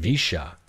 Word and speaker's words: Villa: Listeners, definitely check out it's spelled V-I-L-L-A, like Villa, Via La Angostura, which is pronounced Visha.